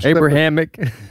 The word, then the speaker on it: Abrahamic: I'm Abrahamic.